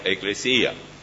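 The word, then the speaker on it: eclesía (ἐκκλησία): Iglesia es una palabra que viene del griego, que significa que se la pronuncia eclesía.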